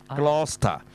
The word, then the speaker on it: Gloucester: 下面這個就在香港、上面一個就在九龍、香港其實我們有過羅士打行嘛、但呢你如果你說照聘呢你說 Gloucester 過羅士打道嘛、 Gloucester Road 呢你又錯了、跟那個 Leicester 一樣其實個 C 字呢、是不發音的中間的 C 字、所以實際上這條路呢就叫做過羅士打、就叫 Gloucester、死不死 Gloucester。